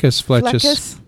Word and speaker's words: Flechas: Now, Dr. Jorge Flechas, did I say that right? – Oh, Flechas.